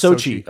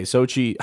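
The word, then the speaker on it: Xochitl: It's pronounced Sochi. – Okay, Sochi. I was so far off.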